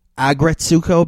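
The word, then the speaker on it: Aggretsuko: I'm obsessed with a new anime show on Netflix called, I'm going to say this wrong, Agresuko. It is about an anthropomorphized animal, like mini cartoons. The lead character's name is Retsuko. So it's called Agretsuko because it's about this character, Retsuko, who she's a red panda.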